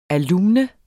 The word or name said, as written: alumne